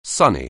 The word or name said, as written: sonny